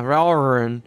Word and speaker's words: Valravn: There's no extra R, though. It's just one R. – Valravn. – Yeah, Valravn.